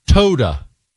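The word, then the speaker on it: Toda (תודה): Toda. T-O-D-A. He even puts how to pronounce it in there.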